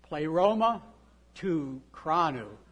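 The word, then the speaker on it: Pleroma to cranu (πλήρωμα τοῦ χρόνου): Okay, I'm going to give you a Greek pronunciation le- lesson today. Okay, we're going to have you say that the pleroma, why did I say it right? Pleroma, cranu. So can we say that together? Pleroma to cranu.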